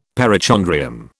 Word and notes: perichondrium: British English

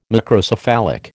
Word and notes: Macrocephalic: American English

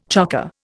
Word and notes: chocker: Indian

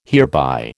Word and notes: hereby: American English

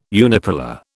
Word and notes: unipolar: British English

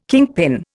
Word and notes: kingpin: Indian

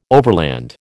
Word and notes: overland: American English

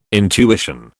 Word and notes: intuition: British English

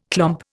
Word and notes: clomp: Indian

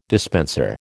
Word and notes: dispenser: American English

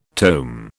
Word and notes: tome: British English

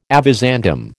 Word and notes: avizandum: American English

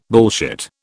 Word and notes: bullshit: British English

